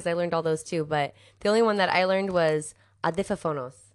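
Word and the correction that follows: audífonos: Did I say that right? – No. – Damn it. – Slow it down. – Adifonos. – A-U-D. – This, I don't like this word. – Audifonos.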